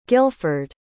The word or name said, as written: gilford